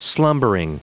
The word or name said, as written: slumbering